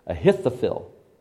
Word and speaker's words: Ahithophel: Let me pronounce that correctly. Ahithophel.